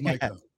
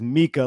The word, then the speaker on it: Micah: We love Micah. – Call my co-host out for referring to him as Mika, like he's Mika Zibanejad in the New York Rangers.